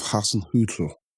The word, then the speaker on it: Hasenhüttl: We've got to move on to Ralph Hasenhüttl.